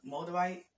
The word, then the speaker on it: moldavite: It looked like moldavite. That's how you pronounce it.